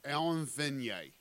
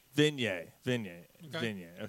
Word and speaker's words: Vigneault: Alan Vigneault. – Vigneault.